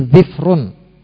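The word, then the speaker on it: dhufrun (ظفر): Ini juga bahasa yang sahih, sehingga boleh baginya ketika menglafadzkan dhufrun yang artinya kuku, dia mendhamah huruf dhadnya dan mensukun huruf fa-nya, dhufrun. Itu boleh. Dan terdapat pula sebagian pengucapan dengan mengkasrah huruf dhad dan mensukun huruf fa menjadi dhifrun.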